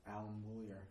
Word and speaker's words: Bullier: Varsity wrestling, Alan Bailey. I always get his last name wrong. – Bullier. – No. – Alan Bullier. – It's Bullier? I'm way too phonetic. – Yeah. – All right. – It's French.